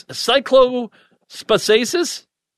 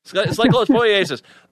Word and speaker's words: cyclosporiasis: If you don't mind, and I don't, how do you say this? Cyclospasasis? – Cyclosporiasis.